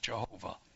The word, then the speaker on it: Jehovah: But as my name, Yod-Heh-Vav-Heh, it's okay to pronounce it Jehovah, because we don't know how to pronounce it otherwise.